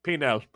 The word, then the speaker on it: Penel: Captain Penile, can I give you a... – Penel. – Forgive me, my accent just... – It's Penel. It's spelled actually just like it sounds. Penel.